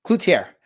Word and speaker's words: Cloutier: Cloutier.